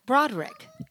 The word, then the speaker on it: Broderick: Broderick. Two syllables. – Matthew Broderick. – Yeah, two syllables.